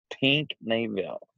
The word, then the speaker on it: Pinckneyville: It's Pinckneyville. Yeah, that's what it is. There's a silent C in there too.